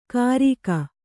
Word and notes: ಕರಿಗ: Kannada

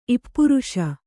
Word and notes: ಇಪ್ಪುರುಷ: Kannada

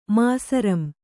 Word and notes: ಮಾಸರಂ: Kannada

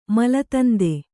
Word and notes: ಮಲದಂದೆ: Kannada

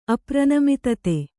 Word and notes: ಅಪ್ರನಮಿತತೆ: apranamitate

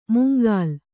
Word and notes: ಮುಂಗಾಲ್: mungāl

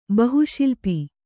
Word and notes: ಬಹುಶಿಲ್ಪಿ: kannada

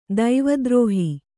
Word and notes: ದೈವದ್ರೋಹಿ: daivadrōhi

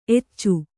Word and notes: ಎಚೆ: ece

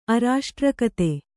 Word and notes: ಅರಾಷ್ಟ್ರಕತೆ: arāṣtrakate

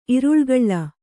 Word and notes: ಇರುಳುಗಳ್ಳ: iruḷugaḷḷa

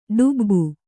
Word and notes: ಡುಬ್ಬು: ḍubbu

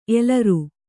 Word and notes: ಎಲರು: elaru

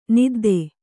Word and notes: ನದ್ಧ: naddha

